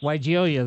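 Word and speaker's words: Weigela: I'm not sure how to pronounce it. Weigelia plants. – Weigelia, that sounds perfect.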